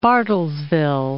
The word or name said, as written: Bartlesville